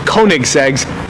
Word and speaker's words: Koenigsegg: We got the Koenigsegg, Swedish Koenigseggs. Koenigseggs. – It is pronounced Koenigsegg.